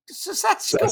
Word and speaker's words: Saskatchewan: It's Saskatch- Saskatchewan.